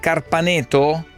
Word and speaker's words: Carpaneto: Carpaneto, ho detto bene, Carpaneto? – Sì.